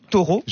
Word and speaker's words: Thoreau: Thoreau. – J'ai jamais réussi à le prononcer, mais bah, on a le droit de dire Thoreau. Bah, très bien. Comme un taureau.